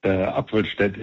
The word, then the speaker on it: Appelstad: Appelstad is German.